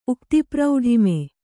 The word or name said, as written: ಉಕ್ತಿಪ್ರೌಢಿಮೆ